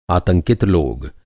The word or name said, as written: आतंकित